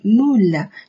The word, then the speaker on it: moule: 叫做 une moule，une moule。这里有个组合 u m u l，最后的 e 是不发音的 une moule，une moule。